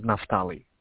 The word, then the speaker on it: Naftali: My name is Naftali.